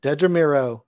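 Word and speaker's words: Dedra Meero: Dedra? – Dedra Miro.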